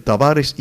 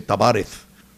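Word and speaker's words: Tavárez: Y si usted quiere, para que le sea más fácil, porque lo que pasa es que cuando uno pronuncia los apellidos, el Tavares y el Justo, este terminando en Z, que se pronuncia como una S, en esta parte del mundo, en España se dice Tavares.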